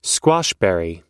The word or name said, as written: squashberry